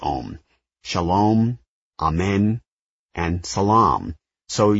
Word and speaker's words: Om: Om is usually pronounced as 3 separate sounds. A, O, and Om.